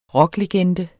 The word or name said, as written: rocklegende